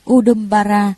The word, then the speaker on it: Udumbara: Nguyên âm Brahma, Ấn Độ đọc là Udumbara,